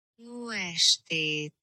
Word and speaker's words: Jó estét: Jó estét.